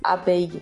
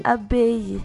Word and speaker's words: abeille: Abeille. Abeille. Ah, que coisa linda. É como se tivesse outro I. Entendi. Então não tem L.